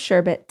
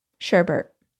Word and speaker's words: sherbet: thank you for wearing the color of sherbet today. Sherbet? Sherbert. I grew up saying sherbert, but it's spelled sherbet.